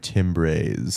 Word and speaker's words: timbre: We have different timbres. – I sound like... – Sweetie, it's pronounced... Timbre.